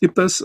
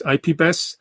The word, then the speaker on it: IPBES: Und laut IPBES, laut IPBES, ähm, also die korrekte Aussprache ist IPBES und nicht IPBES. Ihr habt gesehen, ich mache es auch noch falsch.